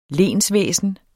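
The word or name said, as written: LENSVÆSNET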